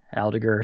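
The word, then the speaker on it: Aldeguer: And then Della Porta, Agüero, Fernandez, Ramirez, Adliger, who is the 16-year-old Spanish wonder. I got to work on Aldiger. Aldiger is how you say it.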